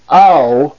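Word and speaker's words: au (αυ): The first diphthong is alpha ypsilon. That's a and u, a u. Its pronunciation is au, au, as in the word now, the O-W, ow, now, alpha, ypsilon, ow.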